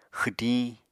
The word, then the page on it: xwdiñ: Apatani